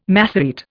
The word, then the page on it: Masorete: Indian